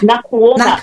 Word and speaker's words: Nakuona: I want to talk to you next, about, let me make sure I'm saying it correctly, Nakuona. Am I saying it right? I'm sorry. – Yes, Nakuona. – Nakuona.